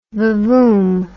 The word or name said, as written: vavoom